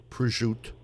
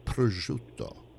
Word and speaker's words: prosciutto: And so, it's not prosciutto, it's prosciutto. – Right. – It's not cubbygall. Right. It's capicola.